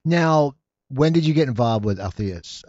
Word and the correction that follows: Antheus: I pronounce it. – Antheus. – Antheus. I always pronounce it wrong.